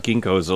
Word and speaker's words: ginkgo: Let's talk about ginkgos a little bit.